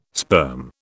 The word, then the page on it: sperm: British English